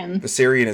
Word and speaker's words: Viserion: Viserion is killed.